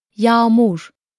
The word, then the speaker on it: yağmur: There is no direct pronunciation in English, but it can be imagined as the bridging sound you make between the vowels in the word sound. An example is yağmur, which means rain.